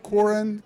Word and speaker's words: Korin: Joel Corin, K-O-R-I-N.